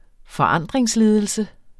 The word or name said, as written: forandringsledelse